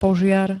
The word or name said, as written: požiar